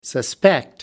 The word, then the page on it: suspect: American English